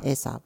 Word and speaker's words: Aesop: I don't know how to pronounce this next one, so I'm going to say Aesop. – Aesop.